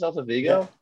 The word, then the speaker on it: Celta Vigo: Celta Vigo? Is that how you pronounce it? Celta Vigo? – Yeah.